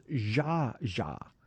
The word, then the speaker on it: Zsa Zsa: It's Zsa Zsa. You said Zsa. Well, I don't know what they... this is a major... – It's Z-S-A.